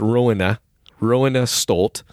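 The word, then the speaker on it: Roine Stolt: And then on guitar, you have, and I figured out I was saying it wrong, it's Rowena Stolt, who was in the Flower Kings.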